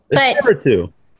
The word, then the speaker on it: Espiritu: Okay, it's Giovannie Espiritu. – Espiritu. – Yeah, like I don't really care.